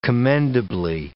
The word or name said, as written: commendably